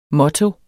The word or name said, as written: motto